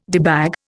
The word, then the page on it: debag: Indian